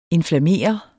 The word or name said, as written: inflammere